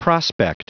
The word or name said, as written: prospect